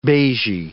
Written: beigy

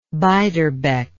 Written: beiderbecke

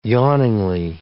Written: yawningly

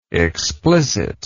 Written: explicitness

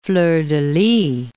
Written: fleur-de-lis

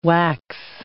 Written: wax

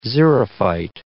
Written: xerophyte

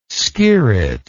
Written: skirret

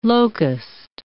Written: locust

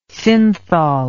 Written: synthol

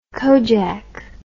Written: kojak

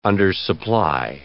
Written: undersupply

